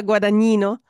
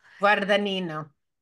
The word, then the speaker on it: Guadagnino: Guad- Luca Guadagnino. – Guadagnino. Guadagnino.